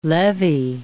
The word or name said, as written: levee